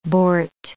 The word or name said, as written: boart